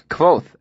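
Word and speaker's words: Kvothe: "It's Quoth, actually," I said absent-mindedly. – "The E is silent."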